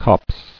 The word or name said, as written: Copse